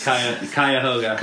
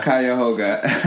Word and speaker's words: Cuyahoga: So you went to, let me see if I pronounce this right, Cuyahoga Valley Christian Academy? – Here it goes, Cuyahoga. – Cuyahoga.